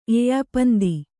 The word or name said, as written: ಎಯ್ಯಪಂದಿ